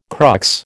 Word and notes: crux: American English

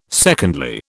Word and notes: secondly: British English

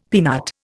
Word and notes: peanut: Indian

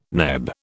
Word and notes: NEB: British English

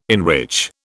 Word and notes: enrich: British English

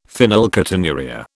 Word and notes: phenylketonuria: British English

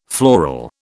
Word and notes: floral: British English

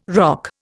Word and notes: ROC: Indian

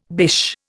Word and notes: bish: Indian

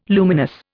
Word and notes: luminous: Indian

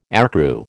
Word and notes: aircrew: American English

